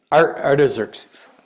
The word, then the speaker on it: Artaxerxes: And the word to restore the city came from Xerxes in, is that how you say it? Yeah, Artaxerxes, thank you, in Nehemiah 2.